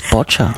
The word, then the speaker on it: boccia: Before you walked in, we both had a look and went, what's boccia? – Yeah, look, you said it correctly, so that's the start.